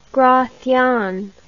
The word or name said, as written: gracian